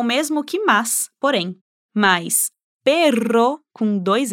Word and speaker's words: perro: Pero, pero, perro, perro. Nesse caso, confundir os sons faz mudar a palavra, então lembre-se que os dois R's precisam ser pronunciados com um som forte.